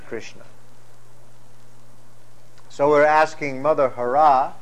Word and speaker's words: Hara: And the word Hare, now in the root form, it's Hara, H-A-R with a long A, Hara.